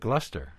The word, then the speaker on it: Gloucester: The reason is, if you write out the first part of it, Glouce, it's Glouce. Then you add ter, and it's ter. – So it's Gloucester.